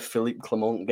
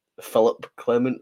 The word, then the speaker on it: Philippe Clement: I did French, so I was happy with Philippe Clement getting his right pronunciation. I think Fiona called him Philip Clement once, Philip at least, in the French part of me wasn't too pleased.